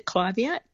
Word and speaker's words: Clivia: Is it pronounced? Is it Clivia or Clivia? – I call it a Clivia. – Yep. But what's the question? – I was just wondering about its sun requirements and its water requirements. – So first of all, I pronounce it Clivia, but it is a tomato.